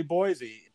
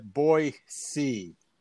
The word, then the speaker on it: Boise: But it's not really Boise. People in Idaho call it Boise. It's a soft C sound, and it's not a Z sound.